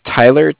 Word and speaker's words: Tyler: My name is Tyler, T-Y-L-E-R, and I'm calling with Cardiff. It's spelled C-A-R. D-I-F-F, as in Frank, Frank.